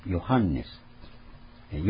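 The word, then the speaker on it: Johannes: それでドイツ語なんかの発音ではヨハンネス、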